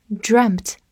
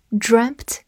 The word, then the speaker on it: dreamt: 这里用到了 dream 的过去式，就是后面直接加一个 t，但是发音变成了 dreamt。 e 字母组合在这里变成了小口 e，dreamt。好，跟着我慢读一遍：I dreamt about you。